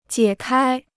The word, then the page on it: 解开: jiě kāi